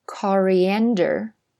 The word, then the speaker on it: coriander: 那英式英语呢，有另外一个发音，其实应该是另外一个单词了，叫 coriander。大家跟我再读两遍啊，coriander。那它的重音呢是在 ander 那个 an 上，coriander。